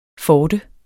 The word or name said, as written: forte